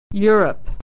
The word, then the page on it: Europe: /y/